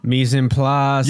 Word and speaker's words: mise en place: It's a three-word French term that rhymes with demi-glace. – Okay. – Well, the last part rhymes with demi-glace. – Mise en place.